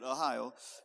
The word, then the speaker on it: Ahio: He says, So they carried the ark of God on a new cart from the house of Abinadab, and Uzzah and Ahio. I thought, is that how you pronounce that? And I checked it. That's how you say it, Ahio.